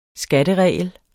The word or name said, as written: skatteregel